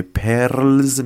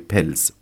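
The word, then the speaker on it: Perls: چرا من از این به بعد به آقای پرلز میگم آقای پلز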